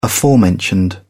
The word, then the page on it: aforementioned: English; British English